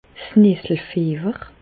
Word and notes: 's Nesselfiewer: Bas-Rhin; Reichshoffen

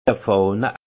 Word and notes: e Fàhne: Haut-Rhin; Munster

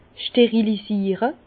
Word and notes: 's Sterilisiere: Bas-Rhin; Herrlisheim

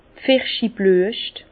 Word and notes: d' Pfirschiblüescht: Bas Rhin; Herrlisheim

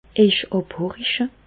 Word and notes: Ejch àbhoriche: Bas Rhin; Reichshoffen

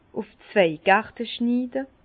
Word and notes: ùf zwei Gerte schniide: Bas-Rhin; Herrlisheim

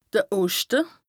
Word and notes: de Oschte: Bas-Rhin; Strasbourg

